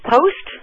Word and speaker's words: Post: I am Victoria Post. Yes, it rhymes with host, so there we go.